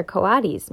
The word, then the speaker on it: coatis: These are coatis, Miss Brisbane said, reading from the letter. Pronounced co-a-tis.